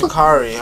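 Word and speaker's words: Sha'Carri: Nigga, you mispronouncing the fuck out her name. – Right. – Shakari, nigga. Shakari. This nigga said Shakara.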